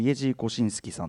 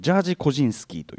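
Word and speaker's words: Jerzy Kosiński: イエジー・コシンスキーさんというこれはまあ英語風発音だと,、えーと、ジャージ・コジンスキーというね、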